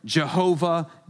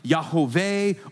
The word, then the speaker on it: Yahweh: No one knows how to pronounce God's name, but we say in English a terrible Jehovah. That's not good. It's nothing like that. It's more like Yahweh or Yahweh is a better way if you're going to pronounce the name of God, his personal name.